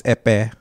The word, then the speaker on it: Ekpeye: And the language we speak, my tribe speaks, is Epe. – Epe. Epe? – Not even close. Yeah. It's spelled E-K-P-E-Y-E. And you gotta kind of bath the Epe.